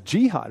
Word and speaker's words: Jehad: It was Jihad. It's spelled different, J-E-H-A-D.